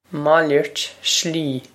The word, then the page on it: Malairt slí: Mal-ert Shlee